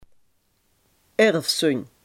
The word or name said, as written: erfzonde